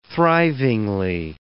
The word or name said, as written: thrivingly